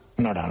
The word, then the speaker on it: Norah: ¿No cambia la pronunciación o qué? – No cambia. – Norah, ¿no? – Norah. La de Ibsen suena igual.